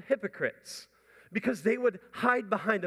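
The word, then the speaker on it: hypocrites (ὑποκριτής): It's pronounced hypocrites. Let me hear you say hypocrites.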